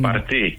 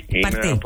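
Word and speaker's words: Parti: Το νέο κόμμα, λοιπόν, αυτό το Γενή Παρτί ή Πάρτι, πώς το προφέρουν οι Τούρκοι? – Παρτί. – Παρτί, καλά το φαντάστηκα, ναι.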